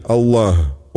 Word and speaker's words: Аллах: Например, если человек произносит «нет божества, кроме Аллаха», то имя Создателя Аллаха он должен произнести правильно. Здесь особое внимание уделяется последней букве «г», напоминающей по произношению украинскую «г».